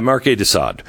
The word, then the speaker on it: Marquis de Sade: S-A-D. – Mm-hmm. – Yeah, Marquis de Sade.